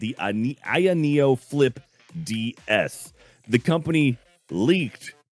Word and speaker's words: AYANEO: Aya Neo, A-Y-A-N-E-O.